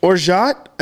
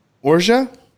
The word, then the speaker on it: orgeat: Orgeat, an almond, or some pronounce it orgeat, or some even say orgeat. But just say orgeat.